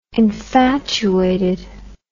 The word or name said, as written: infatuated